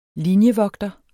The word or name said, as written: linjevogter